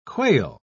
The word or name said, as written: quail